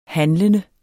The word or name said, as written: handlende